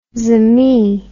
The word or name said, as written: zemi